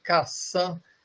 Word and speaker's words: Cassin: Se escreve Cassan, C-A-S-S-I-N, né? Então, é Os Intraduzíveis.